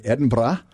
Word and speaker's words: Edinburgh: I know you were born in Edinburgh. – That's beautifully pronounced. I love it. – Thank you. Edinburgh.